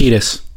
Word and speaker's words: Kiedis: And so, you know, there was Anthony, how do you say his name from Red Hot Chili Peppers? – Kiedis.